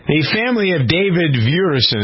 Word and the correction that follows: Duerson: Is that how you pronounce this football player's name? V-E-V-U-E-R? – Duerson, actually. – What is it? – That's a typo. It's Duerson.